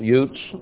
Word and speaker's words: Uz: Here we find Utes, or Uz, but would be pronounced Utes, and we have the Utes here in Utah and other parts of the Southwest.